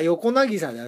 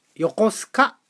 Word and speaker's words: 横渚: まあ普通に考えれば横渚ですが、ここではなんと横須賀と読むんですね。